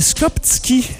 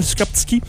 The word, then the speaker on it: Skoptsky: Ça s'appelait les Skoptski. S-K-O-P-T-S-K-Y. Ça se prononce très mal, Skoptski.